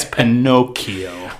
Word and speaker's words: Pinocchio: The CH in Italian makes a K sound, Omar. It's Pinocchio.